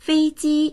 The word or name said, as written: fei1ji1